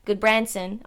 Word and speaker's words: Gudbranson: I don't expect Gudbranson, oh, I said his name right.